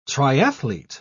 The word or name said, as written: triathlete